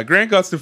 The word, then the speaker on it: Gustin: Number one would definitely be Grant Gustin. Did I say that right? – Yeah. Yes. Yeah. Grant Gustin for sure.